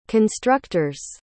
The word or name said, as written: constructor's